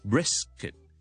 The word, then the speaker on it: brisket: 我哋牛腩咧就系 brisket，听住咯，bris，大家个音节都系好短嘅，但系冇咗嗰个用脷尖顶上去 T 音位嗰个急停嘅感觉，听住咯，brits，ok，brits，b r i t s，好啦，brisket 咧就冇 T，咁呢个咧就。直接 bray 完之后咧，好快地入到去 S 音，brisket，咁就唔好将佢读成咧 brisket，咁就会好奇怪噶啦，知唔知道？